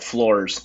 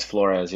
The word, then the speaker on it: Flores: Yeah, some people call it Flores, but no, it's Flores, yeah. – Flores. It's spelled F-L-O-R-E-S, so that's just dumb.